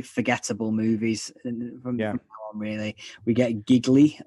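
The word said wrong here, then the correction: Gigli: It's pronounced Geely. And Ben Affleck makes a point in the film. This is one of the things that's so wrong with that movie. He's got this big thing in the film where he keeps telling people that my name's Geely. It rhymes with really. And I'm thinking, no, it doesn't. Geely does not rhyme with really, Ben.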